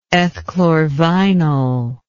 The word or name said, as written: ethchlorvynol